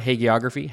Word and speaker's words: hagiography: which is a straight up like hagi- hag- how do you pronounce the word hagiography? Hagiography. – That's fine.